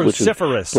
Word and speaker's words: cruciferous: Cruciferous.